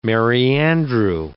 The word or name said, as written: merry-andrew